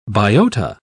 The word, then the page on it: biota: British English